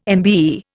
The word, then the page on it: MBE: Indian